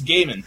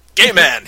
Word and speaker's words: Gaiman: I think it's Gaiman. – Okay, so Neil Gaiman. Gay man.